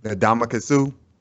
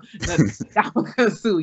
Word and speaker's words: Ndamukong Suh: Ndamukong Suh. – Yes. Where, I get stuff from? – Ndamukong Suh.